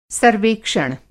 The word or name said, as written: सर्वेक्षण